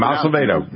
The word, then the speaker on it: Acevedo: That's his name, Acevedo. That's how you pronounce the name, Acevedo.